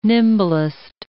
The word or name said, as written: nimblest